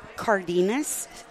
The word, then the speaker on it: Cardenas: Cardenas. – Cardenas? – C-A-R-D-E-N-A-S.